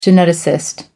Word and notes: geneticist: American English

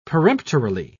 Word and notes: peremptorily: British English

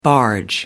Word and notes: barge: American English

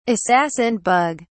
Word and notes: assassin bug: British English

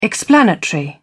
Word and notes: explanatory: British English

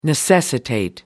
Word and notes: necessitate: American English

